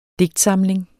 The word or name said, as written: DIGTSAMLINGERNES